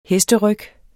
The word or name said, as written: hesteryg